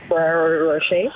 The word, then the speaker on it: Rocher: So I got some Rocher Refrain, or how do you say that? The chocolate that I bought the other day? – Rocher? – Yes,